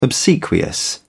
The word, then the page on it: obsequious: British English